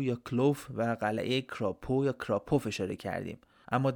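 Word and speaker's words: Kropow: کراپو یا با تلفظ لهستانی شاید کراپوف درست باشه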